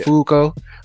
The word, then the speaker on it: Fuko: Fuko. But it's like a long, it's like they pronounce the U long. So like, yeah. Fuko, but Fuko, aka Unluck.